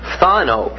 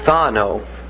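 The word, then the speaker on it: Thano (φθάνω): Now the word prevent is thano. Now that's spelled P-H-T-H. We say, thank God we don't have words like this in English. Thano, P-H-T-H-A-N-O. Thano.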